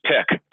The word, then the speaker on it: Picc: And my nickname in high school is just Pick, P-I-C-C.